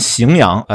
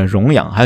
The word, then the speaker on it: 荥阳: 有名的几个例子，这个我不知道，黑明老师你知不知道？一个就是河南荥阳，呃，容易念成呃荣阳，还有扬州，呃，去扬州玩，这个邗江区，容易念成干江区。